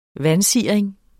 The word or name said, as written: vansiring